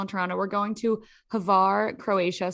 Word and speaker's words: Hvar: Hvar, and then they show it. It's spelled like H, pronounced like Havarti.